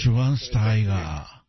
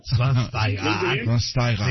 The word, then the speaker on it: Schweinsteiger: Schweinsteiger. – Después de 37 años de vida, ya sé cómo se pronuncia. Bastian Schweinsteiger. – Schweinsteiger.